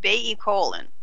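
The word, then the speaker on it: baicalein: It's B-A-I. C-A-L-E-I-N, but it's pronounced baecolin.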